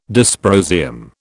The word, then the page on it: dysprosium: British English